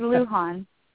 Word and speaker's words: Lujan: It's Lujan.